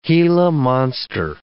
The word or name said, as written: Gila monster